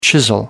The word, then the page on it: chisel: American English